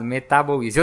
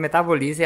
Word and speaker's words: Metaboize: Legal, e eu tava conversando com ela antes de gravar, como esse nome é bem pensado, Metaboize. Eu tava falando errado, tava falando Metabolize e ela me corrigiu, mas é muito legal esse nome, né? – Obrigada.